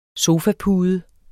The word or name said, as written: sofapude